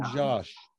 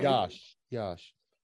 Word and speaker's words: Iași: This was in Iași. – Oh, okay. – Iași. which is spelled Iasi.